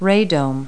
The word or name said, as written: radome